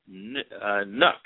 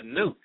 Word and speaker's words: NUC: We're going to talk about the Intel Nook. – Right. – I almost said nuke. – Well, some people pronounce it nuke.